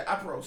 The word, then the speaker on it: Aperol: Yeah, Aperol. Sorry, said it wrong.